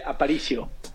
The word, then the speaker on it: Apricio: You said it well, Sam. Manuel Apricio. – Apricio. Yes. Yes, sir.